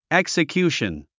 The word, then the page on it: execution: èksəkjúːʃən; エクサキューション